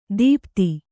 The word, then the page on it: ದೀಪ್ತಿ: dīpti